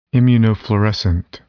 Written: immunofluorescent

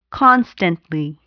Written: constantly

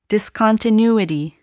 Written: discontinuity

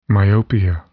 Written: myopia

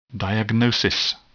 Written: diagnosis

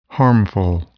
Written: harmful

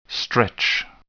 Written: stretch

